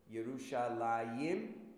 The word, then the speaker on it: Yerushalayim: Yerushalayim, right? Yeh, but there's no yud there.